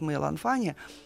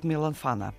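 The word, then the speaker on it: Мэй Ланфана (梅兰芳): от Мэй Ланфаня, хочу правильно говорить, от Мэй Ланфана, да, но по-русски как-то от Мэй Ланфаня приходится смягчать, это сюжеты, которые известны на протяжении тысячелетий.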